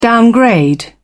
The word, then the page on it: downgrade: British English